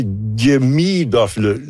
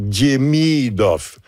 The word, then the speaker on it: Demidov: Et si on veut être pointu, c'est Die. Il y a un petit Diemidov. L'accent est sur la deuxième syllabe. Donc, Diemidov. C'est sûr que quand il vient compter un but, ça va être Demidov quand même.